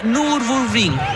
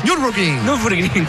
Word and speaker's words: Nürburgring: Y ganó las 24 horas de Nürburgring. Lo pronuncié muy mal. – Nürburgring, claro.